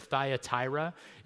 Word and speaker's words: Thyatira: It's the church of Thyatira is how you pronounce that.